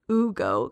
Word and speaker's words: Hugo: His name is H-U-G-O, but we pronounce it Ugo, because you don't pronounce the H in Spanish.